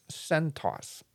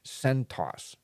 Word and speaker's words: CentOS: And some people call this CentOS, is how I understand you pronounce it, CentOS. But it's written as CentOS, right? Lowercase ENT, bigger OS. But people call it CentOS.